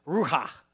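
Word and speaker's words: ruha (רוּחַ): That made it sound funny, but that's how you pronounce it. It's ruha.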